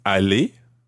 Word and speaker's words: aller: A-L-L-E. E-Z. – Uh-huh. Est-ce que vous allez aller? – A-L-L-E-R. – Good. Okay, they sound exactly the same. Allez, EZ. Allez, ER. And of course here we're saying, est-ce que vous allez?